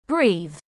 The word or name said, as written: Breathe